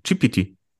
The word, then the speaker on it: GPT: GTP, GPT. – Das P kommt vom T. T, ja. – Gut. Also, ihr Lieben da draußen, ihr wisst, Chat GPT. So spricht man es aus.